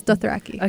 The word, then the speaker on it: Dothraki: And amongst those languages represented is Dothraki. Is that right? – Yes, from Game of Thrones. – Is that how you say it? – Yes, Dothraki.